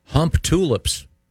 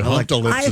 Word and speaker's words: Humptulips: In Grays Harbor County, Washington, there is a town called Hump Tulips. I wonder if they pronounce, maybe it's pronounced Hump Tulips. – Boy, Humptalips is almost more offensive.